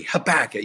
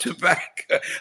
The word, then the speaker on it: Habakkuk: Habakkuk, you know, rhymes with tobacco. We don't do that, but we do. Habakkuk, not tobacco.